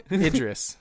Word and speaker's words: Idris: Did I even get his name right? Dreebus Elba? – Idris. Idris Elba.